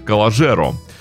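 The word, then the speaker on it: Calogero: «Калогеро» э, также достаточно популярен и у российской аудитории песня, ну вообще э, звук Г, буква Г озвучивается, так что можно его произносить и как коллажеро.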